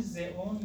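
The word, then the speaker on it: Xeon: این زئون یا زیان هم تلفظ میشود تا یکی اسم در واقع ساخته شد.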